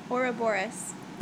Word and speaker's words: Ouroboros: How do you pronounce the mirror? Ouroboros. That's what I say.